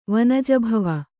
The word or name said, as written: ವನಜಭವ